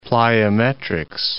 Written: plyometrics

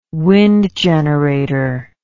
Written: wind generator

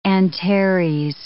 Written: Antares

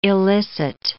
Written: illicit

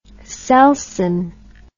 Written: selsyn

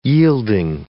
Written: yielding

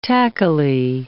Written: tackily

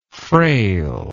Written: frailness

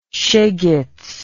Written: shegetz

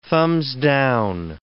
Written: thumbs-down

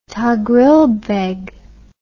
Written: toghrilbeg

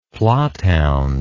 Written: plotthound

